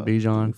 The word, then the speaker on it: Bijan: Yeah, because of Barjon, Bijan. We'll get his name right by the end of the year.